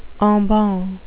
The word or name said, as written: en banc